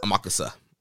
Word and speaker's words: Amakusa: I don't think Yo's winning here, considering the fact that Hiromu is about to face Amakusa. Is that how you say his name? – I think Amakusa? – So, yeah. Yeah. – Amakusa? – Amakusa.